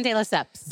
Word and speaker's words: De Lesseps: De Lesseps.